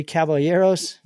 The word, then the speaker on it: Caballeros: So, you know, the only things that were worse on here was three Caballeros.